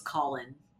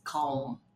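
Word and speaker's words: Colm: Like, I really thought the uncle's name was Colin. – Oh, the uncle. – But it's not Colin. It's Calm. – Yeah. – Yeah. But there's like, yeah. The way they pronounce it, there's almost a second syllable.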